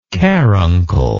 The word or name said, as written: carunculous